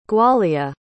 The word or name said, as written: gwalia